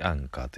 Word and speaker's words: ANCHOR: ANCHOR これでアンカーと読むんですけどもアンカーアプリの方でお聴きくださいあのハロプロで検索すると出てくると思いますまたアンカー